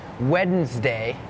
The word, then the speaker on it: Wednesday: It's not wed Wednesday Wednesday D is silent